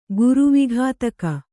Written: ಗುರುವಿಘಾತಕ

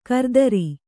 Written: ಗಿರಿಧರ

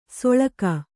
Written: ಸೊಳಗ

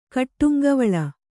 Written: ಕಟ್ಟುಂಗವಳ

